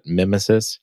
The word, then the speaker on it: Mimesis: So if you're familiar with projects like Faker, or uh, I believe it's pronounced Mimesis, maybe Mimesis. I think it's Mimesis, but like Nemesis, but Mimesis.